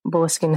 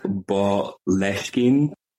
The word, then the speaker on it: Boleskine: However, there have been many ways I have seen Boleskine pronounced. Some people say Boleskine, some people say Boleskine. But me and the Gaelic Oomphies have discussed, and we have decided that it would be pronounced like from the Gaelic Boleskine, which is like the area name. So Boleskine is like the closest I can get to that.